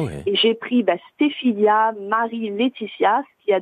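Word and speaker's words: Stéphylia: Et comment comment je l'ai créé Parce que ma fille s'appelle, alors je le dis bien, Stéphilia. – Stéphilia. – alors c'est pas Cécilia, hein, Stéphilia. – Stéphilia, comme Stéphanie au début, quoi. – Voilà, S-T-E-P-H-Y-L-I-A.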